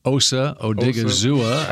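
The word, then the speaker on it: Osa Odighizuwa: Osa Odigizua out of UCLA. – I said it right.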